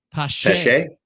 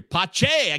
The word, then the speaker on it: Pache: And I got it wrong, by the way. It's Christian Pache. – Pache. Okay. – I want to give a shout out to Baseball Pods, who gave us a pronunciation guide last year. Pache. I can't believe I blew it. Pache.